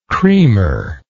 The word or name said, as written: creamer